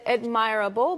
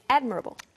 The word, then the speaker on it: admirable: Yes, she said admirable, but it's admirable.